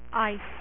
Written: Iceman

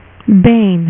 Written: bane

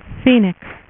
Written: phoenix

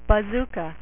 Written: Bazooka

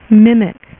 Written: Mimic

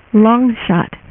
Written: Longshot

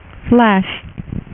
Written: Flash

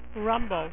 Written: rumble

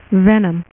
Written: Venom

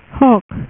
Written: Hulk

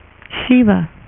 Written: shiva

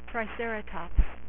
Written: Triceraton

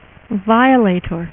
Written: Violator

